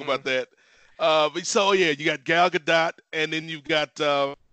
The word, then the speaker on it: Gadot: Gadot. It's Gadot. Yeah. – Okay, because I've heard Gadot, Gadot, Gadot with no emphasis on the T, but it's Gadot.